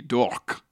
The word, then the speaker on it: Dourque: Scott Dork, but Dork is spelt like... Dork like is spelt Hawk? Like a French name. D-O-U-R-Q-U-E. Dork. – Wow, like talk.